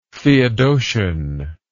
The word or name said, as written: theodosian